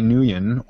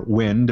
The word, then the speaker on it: Nguyen: The photographer is Johnny Nguyen, or Nguyen, depending on what Vietnamese pronunciation they have.